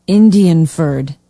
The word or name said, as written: indianford